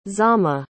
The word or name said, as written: Zama